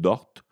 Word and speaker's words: Dort: Son nom, c'est Lugwens Dor. Dor, ou son nom famille, c'est peut-être Dort mais c'est le même que je le prononce, moi.